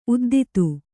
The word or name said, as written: ಉದ್ದಿತು